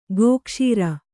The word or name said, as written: ಗೋಕ್ಷುರ